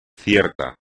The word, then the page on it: cierto: espagnol